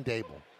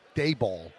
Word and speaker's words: Daboll: It's pronounced like Abel, Brian Daboll. – Yeah. – Everybody, even on ESPN, Daboll.